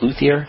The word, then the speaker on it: luthier: Are you a luthier? Is that how you say it? Luthier? – A luthier.